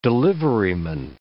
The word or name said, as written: deliveryman